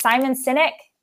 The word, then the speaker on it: Simon Sinek: I never get as, is it Simon Sinek? Am I saying that correctly? – Yes, you okay. are.